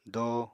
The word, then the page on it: do: apatani